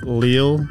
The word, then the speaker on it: Leal: It's like, yeah, it depends. Like, I guess the whitewash to say it is Leal. – Leal. – But if you really like want to be Hispanic with it, Leal or Leal.